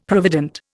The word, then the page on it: provident: Indian